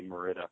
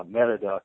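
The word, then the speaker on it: Mérida: I keep on wanting to say Merida because that's how it's spelled in English. But anyways, Merida, and that's so you fly into Merida, and you get picked up right from the airport.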